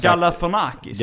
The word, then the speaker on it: Galifianakis: Mm, men, men eh, Zack eh, var då en av dina, jag kan inte uttala hans efternamn, Galifianakis. Galifianakis. – Galifianakis.